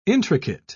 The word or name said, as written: intricate